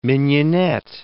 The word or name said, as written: mignonette